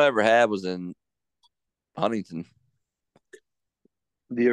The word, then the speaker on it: Huntington: Like if any of you like start talking about, don't call it Huntington. It's Huntington. that T is silent.